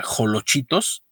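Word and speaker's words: xolochitos: Los tamales colados, torteados y los olochitos, se pronuncia jolochitos, ya saben, mi México querido.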